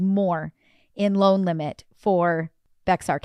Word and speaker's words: Bexar: Oh, hey, Carrie just, she says it's pronounced Bear County.